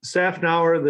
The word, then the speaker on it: Szafnauer: So, hey, Otmar Szafnauer, correct? Am I pronouncing it? – Szafnauer, the Z silent.